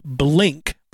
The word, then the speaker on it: Blynk: Okay, it's called Blink, but it's spelled B-L-Y-N-K.